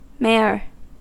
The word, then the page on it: mare: American English